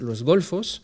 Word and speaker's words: Los Golfos: Comme ça se prononce, G-O-L-F-O-S, Los Golfos.